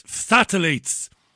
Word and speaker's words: phthalates: She believes that, I can't even pronounce this, is it... Oh, Jesus. I knew I'd get into trouble. It's phthalates. Phthalates or phthalates, that's a chemical.